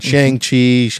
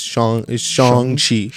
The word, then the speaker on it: Shang-Chi: It's not Shang-Chi. It's Shang-Chi. And that took us a while to get used to that. But anytime you, like, the movie actually taught you very well how to say his name. Because all you have to say is Shang, like Sean with the G.